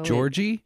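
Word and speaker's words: Gyorgy: It's G-Y-O-R-G-Y. So Georgie? – Yeah, I'd be like Georgie. If we like translated it to our time, that's how I would picture that being. – Hiya, Georgie.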